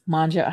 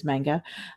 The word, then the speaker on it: manga: Uh, or manja, how you, however you want to pronounce it. Um, I think, but I think the proper pronunciation is manga.